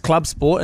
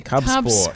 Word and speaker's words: Cub Sport: I think it's pronounced Club Sport. It's a Holden Club Sport. – C-U-B. – Cub Sport.